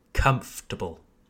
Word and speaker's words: comfortable: But in English, we don't pronounce the or, okay? It's just comfortable, comfortable. Again, we place emphasis on the first syllable, comfortable, not comfortable. It's comfortable.